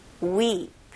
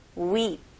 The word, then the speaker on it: weep: 영어로는 모음들이 좀더 길다고 생각하시면 좋을 것 같아요. 그래서 우리나라 발음으로 그냥 whip 이런 식으로 할 텐데 영어 발음은 좀 길게 whip, whip. 오케이, 따라해 볼게요. whip, whip.